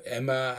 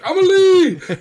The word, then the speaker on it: Amélie: And so even when he was trying to describe Amelie, he couldn't pronounce it correctly. And so he was like, oh, it's this French movie. It's like Emma. I'm like, Amelie!